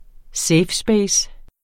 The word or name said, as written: space